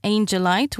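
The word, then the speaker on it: angelit: Well, we only say angelite, which is angelite or angelite.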